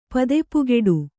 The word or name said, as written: ಪದೆಪುಗೆಡು